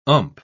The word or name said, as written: ump